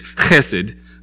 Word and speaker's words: Chesed: So Hesed is this, um, and I think to say it properly in Hebrew, it's, it's Chesed. Um, because you see it's spelled with an H, and you also see it spelled with a CH. And so when you see that, you know that in Hebrew it's a ch sound. – So it's a chesed.